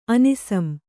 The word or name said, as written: ಅನಿಸಂ